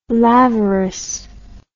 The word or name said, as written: lavoris